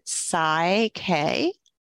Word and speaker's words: PSYCH-K: We pronounce it Psy-K.